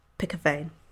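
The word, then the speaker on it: pikkewyn: I spent most of my youth in South Africa near Cape Town, and the Afrikaans for penguin is such a lovely sounding word that I couldn't help but name this colour Pick a Vein, pronounced Pick a Vein."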